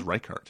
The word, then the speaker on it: Reichardt: How do you say her name? – I think it's Reichardt.